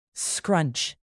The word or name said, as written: scrunch